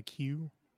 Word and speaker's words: queue: The UEUE in Q is silent. As in lining up in a queue. – Yes. – The UEUE is silent. – Yes, because if it wasn't silent, it would be called quack quack.